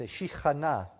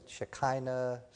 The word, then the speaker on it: Shekhinah: The Shekhinah, I know some people pronounce it Shekinah, Shekinah sounds like that group of Shriners, you know. But Shekhinah, the glory cloud of God, where he dwelled there.